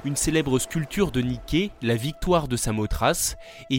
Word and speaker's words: Nike: En anglais, Nike et Nike se prononcent de la même façon. – Nike.